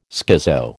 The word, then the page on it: schizo: American English